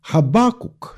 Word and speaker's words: Хаббакук: Во многих других национальных переводах Библии «Хаббакук», собственно, так и остается. Ударение действительно делается на второй слог и последний звук «К».